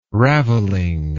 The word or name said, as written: raveling